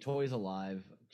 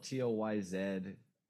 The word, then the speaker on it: Toyzalive: Yeah, so Thursday's YouTube, IG, Toys Alive, just as it's spelled, Toyzalive.